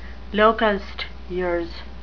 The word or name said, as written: locust years